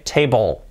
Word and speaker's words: table: La L, letra clave, table, table, table, casi parece que no suena, pero suena de verdad, table, it's not a table.